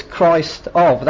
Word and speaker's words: Christof: And if you say it phonetically, it's Christ of. That's how it's spelled, C-H-R-I-S-T-O-F.